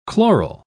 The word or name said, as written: chloral